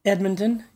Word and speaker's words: Edmonton: Is that how you say it? – Edmonton, yes.